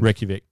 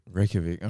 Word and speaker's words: Reykjavik: How do you pronounce that? Sorry, I've been... – Reykjavik. – Reykjavik, all right. – I thought there was a J in there. You don't say the J.